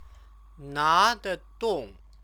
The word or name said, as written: nadedong